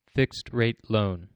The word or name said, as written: fixed rate loan